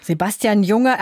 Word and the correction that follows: Sebastian Junger: aber es ist ein Amerikaner, also wird er Sebastian Junger ausgesprochen, nehme ich mal an, auch wenn es ein bisschen seltsam klingt.